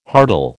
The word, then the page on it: hartal: American English